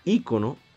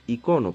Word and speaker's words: icono: La, la palabra ícono su pronunciación correcta debería ser icono, porque semánticamente hablando, de viene la palabra, hasta el griego, viene de iconos.